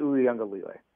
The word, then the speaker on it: Uiagalelei: You got number 3 Clemson at number 5 Georgia with please pronounce the Clemson's quarterback's name for me. – DJ Uiagalelei. – Uiagalelei is, I guess, maybe the proper one.